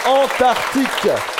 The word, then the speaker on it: Antarctique: Vous n'avez pas oublié le C qu'on n'entend pas. Bonne réponse. Il y a bien un C qu'on ne prononce pas. Antarctique. A-N-T-A-R-C-T-I-Q-U-E, le continent le plus au sud de la Terre.